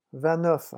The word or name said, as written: vingt neuf